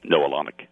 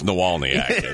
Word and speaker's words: Nawalaniec: If I spelled it to you, Nawalaniec, how would you pronounce it? English teacher? – Noel Onik. – That's Nawalniak. That's how I always say it.